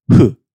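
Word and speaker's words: hu (ふ): That's because it's pronounced more of a hu, hu, like kong hu, but not quite because it's not Chinese.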